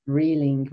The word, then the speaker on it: Rieling: Rieling, right.